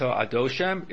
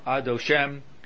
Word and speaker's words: Adoshem: So it's not Baruch Ata Adoshem, it's Baruch Ata Adoshem. The accent is on the last syllable.